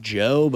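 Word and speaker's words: Djo: Do you understand why his name is pronounced Joe, but it's D-J-O? – I don't know. – Okay. Yeah, because that's how you say that. – Yeah, I would just say Joe. I wouldn't say DeJoe.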